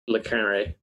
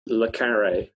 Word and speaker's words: Le Carré: How do you say it? – Le Carré. – What did I say? Le Carré? – Yeah. – So it's an eh, not an uh? – Yeah.